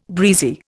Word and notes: breezy: Indian

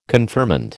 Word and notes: confirmand: American English

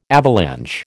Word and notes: avalanche: American English

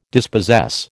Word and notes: dispossess: American English

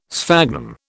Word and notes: sphagnum: British English

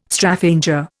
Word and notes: Straphanger: Indian

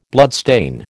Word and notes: bloodstain: American English

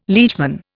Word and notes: liegeman: Indian

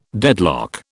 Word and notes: deadlock: British English

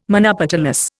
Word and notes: monopetalous: Indian

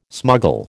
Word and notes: smuggle: American English

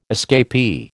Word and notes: escapee: American English

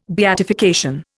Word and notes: beatification: Indian

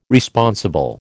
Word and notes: Responsible: American English

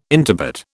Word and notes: intubate: British English

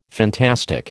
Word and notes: fantastic: American English

